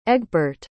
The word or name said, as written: egbert